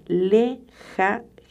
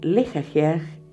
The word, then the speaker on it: Lehahiah: Tu ángel se llama Lejajiaj. Cada J que yo pronuncio es una H. Le. Ah. L-E-H-A-H-I-A-H. Como te dije antes, es una potencia, una potestad. Lejajeaj significa Dios clemente.